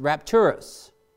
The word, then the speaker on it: rapturus: Now that word caught up in Latin is pronounced rapturus.